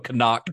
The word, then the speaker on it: Knock: Well, the film's Renfield figure, Knock, or I heard one Nosferatu scholar, I guess you'd call him Knock, which maybe is a German pronunciation. I don't know. – You kept saying that during our live show, but I refused to accept it. – But that's, I mean, these guys know a lot more about Nosferatu than I do, so I assume that their pronunciation is correct. So Knock is what we can go with.